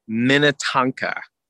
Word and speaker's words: Minnetonka: Minnetonka. You know, like Tonka trucks, just Minnetonka.